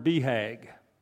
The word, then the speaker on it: BHAG: And it was the first time I heard this word BHAG.